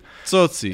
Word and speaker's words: Tsotsi: Tsotsi.